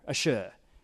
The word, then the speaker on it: Asher: because that's how you say it in Hebrew, Asher. That is how you say blessed.